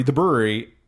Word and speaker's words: Bruery: I wasn't even sure that I was pronouncing it correct when I called it The Brewery, because it just didn't seem logical that you would name the you would call your brewery The Brewery, but not, you know, but spelt that way, but still pronounced The Brewery.